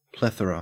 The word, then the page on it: plethora: Australian English